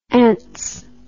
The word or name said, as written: ance